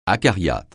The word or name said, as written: acariâtre